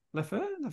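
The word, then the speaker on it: Leffe: Leffa. Is that how you pronounce it? Leffy? – Leffa. As in the beer? – The beer. – From Belgium. – How do you pronounce it? – Leffa. Leffy? – Yeah. Leffa. You said three different pronunciations there.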